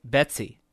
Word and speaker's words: BETC: It's often referred to by its acronym BETC, pronounced Betsy.